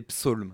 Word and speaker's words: Psalm: C'est Psalm.